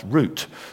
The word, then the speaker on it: Ruth: The name Ruth, actually, in Hebrew is pronounced root,